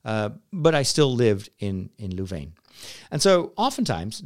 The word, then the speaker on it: Leuven: Which is kind of the French counterpart of the old University of Leuven, or Leuven, which is Dutch speaking.